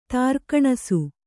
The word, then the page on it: ತಾರ್ಕಣಸು: kannada; tārkaṇasu